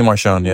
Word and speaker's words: Marchand: Nancy Marchand, yeah.